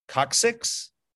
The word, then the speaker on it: coccyx: But it's the, is it pronounced coccyx? Yes. There's a bone that's okay.